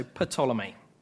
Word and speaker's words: Ptolemy: So the kingdom in the south, based in Egypt, has a number of kings, all of whom are called Ptolemy, beginning with a silent P, so Ptolemy.